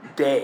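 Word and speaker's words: day (δεῖ): And the first very common one is the word day. It's spelled D-E-I.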